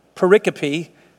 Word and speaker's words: pericope: That's just a word like the word periscope without the S. It means like a passage of Scripture, pericope. But it's pronounced that way without the S, like periscope. Same, just get rid of the S, pericope.